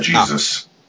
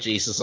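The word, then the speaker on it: Jesús: Just to clear something up, more than likely that's on the cover artists, that's Jesus, not Jesus. – Well, it's spelled like Jesus, I'm sorry. – Yeah, but they pronounce your J's with H's.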